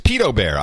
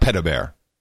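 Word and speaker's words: Pedobear: By the way, I think the correct pronunciation is Peto Bear. I don't like Peta Bear.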